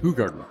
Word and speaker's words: Hoegaarden: So you know that we're all saying that wrong, right? It's actually pronounced Hoegaarden. – Hoegaarden. – I know we like to make the ho jokes. – Sure. – But it's actually pronounced Hoegaarden, yeah.